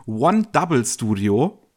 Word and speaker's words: 100 Studio: Äh, die haben ein Studio, was sich im Prinzip schreibt 100 Studio, ausgesprochen werden soll es One Double Studio.